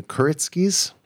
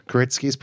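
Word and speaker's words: Kuritsky: It was directed by Luca Guadagnino, written by Justin Kuritskis. You feel okay with that pronunciation? – Sure. – Kuritskis, perhaps?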